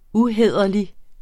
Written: uhæderlig

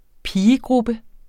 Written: pigegruppe

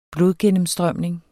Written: blodgennemstrømning

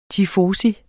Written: TIFOSI

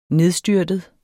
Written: nedstyrtet

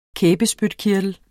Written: kæbespytkirtel

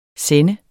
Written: sender

